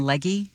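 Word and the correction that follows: Legge: Leggy. – Leg. Hobie corrected you last time. It's pronounced Leg.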